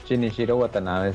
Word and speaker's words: Shinichiro Watanabe: O sea, sí, sí, el, Shinichiro Watanabe, o como le dicen, Shinichiro, Shinichiro Watanabe. – Shinichiro Watanabe, sí, sí es, así es.